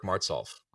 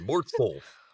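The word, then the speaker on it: Martsolf: E-R-I-C-M-A-R-T-S-O-L-F. I always have that L in there that's never pronounced, but I think I'm going to start pronouncing it. I think it's time. It should be Eric Martzolf.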